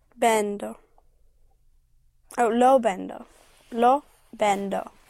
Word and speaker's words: lo vendo: Lo vendo. Yes. That push is important. Again, that down push on the syllable before last is a signal to Spanish ears that you're in the present tense.